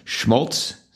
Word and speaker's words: Schmaltz: Number 8 is Spanish Love Songs Schmaltz.